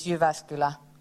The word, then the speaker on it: Jyväskylä: So the correct way of pronouncing this place is Jyväskylä. But since all people coming here from abroad, they always say Jyväskylä. So we're kind of given into that joke, but it's Jyväskylä.